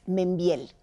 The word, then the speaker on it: Membielle: soy Paulina Mercado, no sé ni cómo se dice, es un apellido francés, Membiel. Se escribe Membielle, yo le digo Membiel, hay quien dice ah.